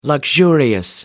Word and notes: luxurious: American English

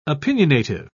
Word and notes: opinionative: British English

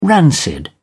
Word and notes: rancid: British English

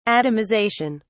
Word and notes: atomisation: British English

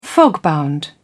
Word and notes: fogbound: British English

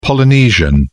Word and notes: polynesian: British English

